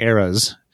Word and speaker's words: Eras: So, I prefer, because it's E-R-A-S, it's Blackwing eras. I prefer to put a long A in that and call it the Blackwing eras.